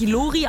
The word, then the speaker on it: Lori: Sie wird wirklich Lori ausgesprochen, kommt aus Berlin, ist sonst Songschreiberin, nicht für sich selber, sondern für andere Artists.